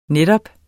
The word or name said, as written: netop